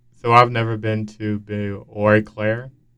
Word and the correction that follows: Eau Claire: Did I pronounce that right? Eau Claire, like O. – Eau Claire. Okay. Oh yeah, yeah. It's like French.